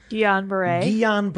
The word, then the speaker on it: Guillain-Barré: The worst thing about it was the correlation between this and the Guillain-Barré syndrome. – How do you pronounce it? – Guillain-Barré.